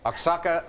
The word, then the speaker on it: Oaxaca: What is Oaxaca? – We're going to give you that one. Our judge is saying, yes, it's pronounced Oaxaca. – Okay. – But that's okay. Oaxaca earns you 1,600.